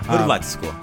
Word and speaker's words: Hrvatsku: Yeah, Hrvatsku. – Hrvatsku, yeah. The accent on the first syllable.